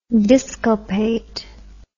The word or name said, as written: disculpate